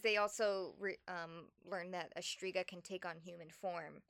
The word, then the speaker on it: Shtriga: It's spelled like the S-H-T-R-I-G-A. Striga. – Okay, so I'm saying it opera and I should. – We're white people. We just say Striga.